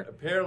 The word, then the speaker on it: Per: Pear like the fruit. It makes it funnier, I think.